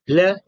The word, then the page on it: l: Sanskrit